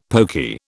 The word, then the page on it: poky: British English